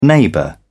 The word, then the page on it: neighbour: British English